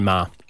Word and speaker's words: Meagher: Ray Ma. – Yep. Spelt Meagher, pronounced Ma. – Well, it's spelt M-E-A-G-H-E-R, but it is Ray Ma. It's a weird one.